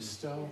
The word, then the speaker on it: Stowe: Is it Stowe? Yes. Did I get her name right? – Yeah.